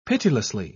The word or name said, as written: pitilessly